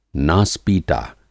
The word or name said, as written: नास-पीटा